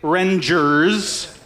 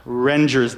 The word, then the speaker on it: Rengers: Carrie Rengers, of course, and I should say Rengers, in case any of you have ever said it another way.